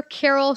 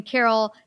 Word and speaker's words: Carol: Uh, but everyone, go to Dr. Carol Shwery. I'm going to spell it for everyone D R and then Carol